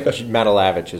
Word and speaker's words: Matulevich: Matalavich is great. – Matalavich. What's going on, John? – Not Matulovich, huh? – Not Matulovich. – Yeah, I get Matulovich a lot, especially from telemarketers, so it's neither here nor there.